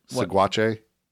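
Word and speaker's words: Saguache: Seguache to buy supplies for his journey. Am I pronouncing that name correctly, Mr. Colorado? – What? – Seguache? – Probably. Okay. Yeah, that sounds right.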